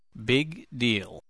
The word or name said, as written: big deal